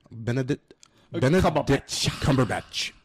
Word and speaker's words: Benedict Cumberbatch: Benedict Cumberbatch.